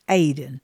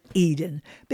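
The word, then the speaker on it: Aden (עדן): It's actually in the Hebrew pronounced Aden, but we all call it Eden because it is talking about the Garden of Eden.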